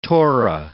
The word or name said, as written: Torah